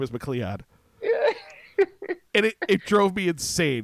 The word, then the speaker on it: McLeod: So it's spelled like McLeod, MCLEOD, like Gavin McLeod. – Sure. – But they keep calling her McLeod as if they all like they wrote it phonetically and they just couldn't, or they're just too stupid.